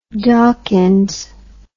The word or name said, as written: dawkins